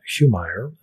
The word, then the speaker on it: Humire: Heumeier, and that's spelled H-U-M-I-R-E.